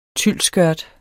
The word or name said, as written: tylsskørt